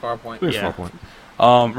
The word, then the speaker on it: Farpoint: We had that Fairpoint or Farpoint. Am I saying that right? Is it Fairpoint? Farpoint VR? – I think it's Farpoint.